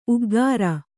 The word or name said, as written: ಉಗುರ್